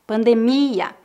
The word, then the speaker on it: pandemia: Preste atenção que a sílaba tônica dessas palavras é no final. Epidemia, pandemia.